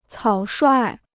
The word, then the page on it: 草率: cǎo shuài